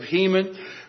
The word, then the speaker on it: Heman: Heman, not the Masters of the Universe character He-Man, but Heman, spelled the same way as He-Man.